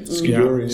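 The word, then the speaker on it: skijoring: You can do everything from, I mean, there's people that do, I think it's pronounced skidjorning. – Skidjoring. – Skidjoring.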